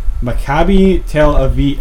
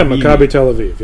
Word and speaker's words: Maccabi Tel Aviv: Maccabi Tel Aviv. – Yeah, Maccabi Tel Aviv.